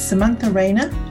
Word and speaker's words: Samantha Rayner: My name is Samantha Rayner and my pronouns are she, her.